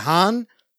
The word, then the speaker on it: Hans: Ich finde ja, find ja schön, dass wenn die, wenn die Amis das aussprechen, klingt es immer wie Hahn Zimmer.